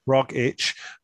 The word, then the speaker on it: Rogic: Is there a correct pronunciation there? So you actually pronounce it silently by just mouthing the word. – According to the Wikipedia, it's Rogic. – Rogic.